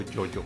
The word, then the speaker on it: JoJo: ¿Jojo o Jojo? – Jojo, porque es, ah. japonés. – Ah, va. – Pero que lo había pronunciado mal.